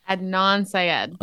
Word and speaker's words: Adnan Syed: Aden Syed? – Adnan Syed.